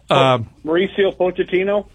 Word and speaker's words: Mauricio Pochettino: Mauricio Pochettino? – Yeah, him.